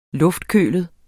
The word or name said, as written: luftkølet